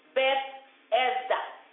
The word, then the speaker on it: Bethesda: And it's a pool near the sheep pool, a place some scholars pronounce it as Bethzapha. Some call it Bethesda.